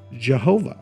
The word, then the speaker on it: Jehovah: Later, translators added vowels to make this YHWH pronounceable, and many people say God is Yahweh. Now, some other people say that if you were to sound it out, the YHWH would be pronounced Jehovah, like Yahowah, Jehovah.